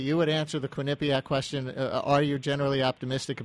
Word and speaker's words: Quinnipiac: Say Quinnipiac. Do me a favor. Say Irish. Quinn. – First. – Quinnipiac. – First syllable emphasis. Quinnipiac.